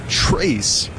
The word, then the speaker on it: Tres: Pereira doing the catching for Rochester, and that is Trace, pronounced just like you would think for Trace, except spelled T-R-E-S.